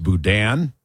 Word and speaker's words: boudin: Apparently, it's pronounced boudin. – Boudin. Boudin. Got it.